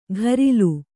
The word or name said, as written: ಘರಿಲು